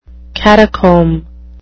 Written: catacomb

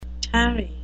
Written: tarry

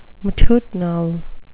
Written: matutinal

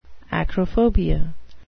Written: acrophobia

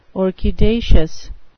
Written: orchidaceous

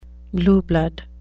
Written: blue blood

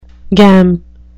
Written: gam

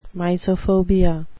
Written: mysophobia

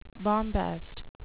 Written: bombast

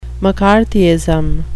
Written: mccarthyism

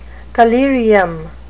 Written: collyrium

